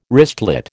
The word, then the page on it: wristlet: American English